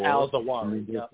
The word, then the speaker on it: al-Zawahiri: I can't even pronounce his name. Ayman al-Zuhawi. You know, he gave Medicare to the poor. – Yeah, Al-Zawahiri. Al-Zawahiri.